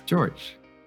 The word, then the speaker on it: George: Is it Jorge or is it George? How do I say your name? – George.